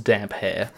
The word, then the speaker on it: Damphair: It is pronounced damp hair. – Yeah, I don't want to gaslight everybody. So Aeron Dampere... That's how it's pronounced, Dampier, and it's spelled Damphair.